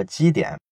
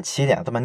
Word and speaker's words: 奇点: 但是在别的地方，比如说物理上啊，或者说其他地方，我们也经常看到有人把它念作基点。但是咱们这次要讲这本书之前呢，啊，我又好好的思考了一下，如果这个词来源于数学的话，我们最好就尊重数学上的这个发音，就按起点这么念就行了。